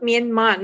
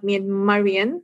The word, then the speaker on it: Myanmar: How do you say that? Myanmarian? – Yeah, they call it Myanmar, not Myanmarian. So Myanmar person.